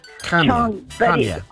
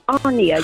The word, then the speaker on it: Chania: But where we stayed in the place called, well, it's spelled C-H-I-A-N-A. – Chania. – It's pronounced Arnia. – Chania, yeah.